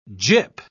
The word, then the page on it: gip: British English